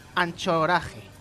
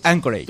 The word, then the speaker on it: Anchorage: Pues ni uno ni el otro, porque la CH en inglés se pronuncia K. – Vale, escocés. – Anchorage.